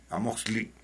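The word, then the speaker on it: amoxtli: El nombre de Amusgo, que se escribía, se pronunciaba Amosco, Amosco, eh, significa eh, tierra de los libros, Amox, que se pronuncia cli, Amoxli, eh, quiere decir libros y con el locativo de lugar, entonces lugar de libros.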